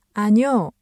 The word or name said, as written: 아뇨